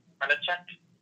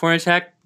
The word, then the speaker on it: Hornacek: How do you say it? – Hornacek? – Yeah, I think that's right. – Yeah. – That'll be my bet. – Yeah, Jeff Hornacek.